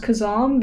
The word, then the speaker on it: Kazaam: I think it should be pronounced Kazam because there are two A's.